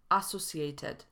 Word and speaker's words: associated: Słowo utożsamiany wymawiamy associated zamiast associated lub associated.